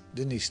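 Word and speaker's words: Denys: En een profiel van de vooral Vlaamse rockster-psychiaters als Dirk de Wachter en Damiaan Denies. Of Denijs, hoe spreek je dat eigenlijk? Denies. – Denies, toch, hè?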